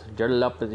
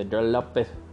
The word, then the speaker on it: George Lopez: Jor Lopez, you have to say it like that. If you're Mexican-American like me, you have to say Jor Lopez.